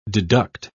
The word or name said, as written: deduct